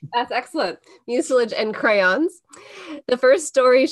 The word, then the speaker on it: mucilage: At school, she made her own books out of manila paper I don't know how to pronounce this word. – Oh, mucilage.